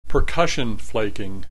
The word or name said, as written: percussion flaking